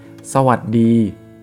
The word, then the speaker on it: สวัสดี: Oke, sawat di, gimana สวัสดี Sawat di itu salamnya orang Thai ya biasanya kalau kamu dengar sodikap gitu ya sebenarnya tulisannya dan cara bacanya adalah sawat di sa wat di gitu ya sawat di Ya kalau di Muslim artinya Assalamualaikum, kalau Hindu Om Swastiastu gitu ya kalau nggak salah.